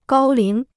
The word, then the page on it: 高龄: gāo líng